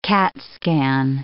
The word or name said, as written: CAT scan